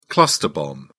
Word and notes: cluster bomb: British English

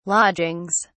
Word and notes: lodgings: British English

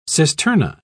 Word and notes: cisterna: British English

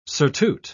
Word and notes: surtout: British English

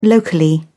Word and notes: locally: British English

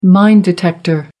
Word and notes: mine detector: American English

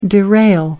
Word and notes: derail: American English

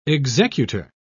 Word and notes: executer: British English

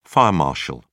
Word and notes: fire marshal: British English

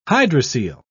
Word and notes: hydrocele: British English

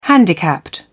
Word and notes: handicapped: British English

